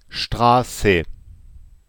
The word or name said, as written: Strasse